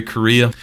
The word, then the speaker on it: Correa: It's written by Larry Correa, and not like Korea the country. It's spelled like C-O-R-R-E-A, if I remember correctly, or I-A.